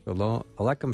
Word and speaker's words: Shalom aleichem: Shalom aleichem shalom.